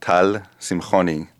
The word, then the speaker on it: Simchoni: So in Hebrew, my name would be pronounced Tal Simchoni.